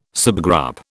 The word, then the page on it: subgroup: British English